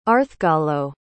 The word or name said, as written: arthgallo